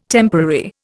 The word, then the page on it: temporary: Indian